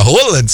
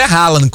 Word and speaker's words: Haaland: E a pronúncia dele não é Haaland, como a gente fala, não. A pronúncia dele é o rola. É rola.